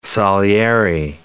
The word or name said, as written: Salieri